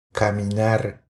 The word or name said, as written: Caminar